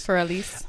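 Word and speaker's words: Für Elise: Is that how you say it? Fur Elise? – Fur Elise.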